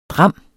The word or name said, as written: dram